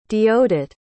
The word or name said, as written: deodat